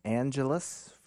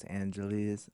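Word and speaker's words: Angeles: Before that, we had Elliott Smith, Angelus from, or I guess Angelis, you know, however you want to pronounce it.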